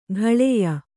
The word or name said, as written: ಕಾಳೇಯ